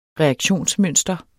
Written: reaktionsmønster

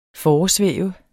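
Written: FORESVÆVEDE

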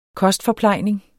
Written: KOSTFORPLEJNINGENS

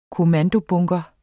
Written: kommandobunker

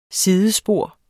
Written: SIDESPOR

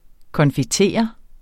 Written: confitere

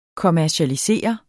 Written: kommercialisere

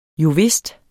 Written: jovist

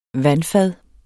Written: vandfad

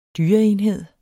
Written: dyreenhed

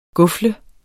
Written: gufle

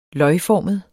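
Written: løgformet

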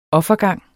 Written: offergang

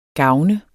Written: GAVNEDES

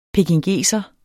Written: pekingeser

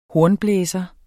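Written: HORNBLÆSERE

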